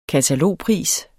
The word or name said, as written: katalogpris